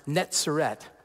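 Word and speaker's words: Netzeret (נצרת): And netzer sounds extremely similar to the original language for Nazareth, pronounced Netzeret.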